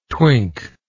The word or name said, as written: twink